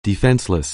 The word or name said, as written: defenseless